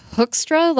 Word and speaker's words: Hoekstra: Mine is not pronounced as it's spelled. It's Hoekstra, like Captain Hook, but you spell it Hoekstra. It's Dutch. And the Dutch do things weird, I guess.